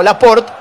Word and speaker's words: Laporte: y cuando la E del final no lleva tilde, como Laporte, no se pronuncia.